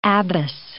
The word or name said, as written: abbess